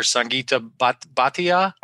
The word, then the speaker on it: Sangeeta Bhatia: You know, I understand that it was a spinoff of MIT, a product of, I'm probably going to butcher her name, but Dr. Sangeeta Bhatia. – Yeah, that's right.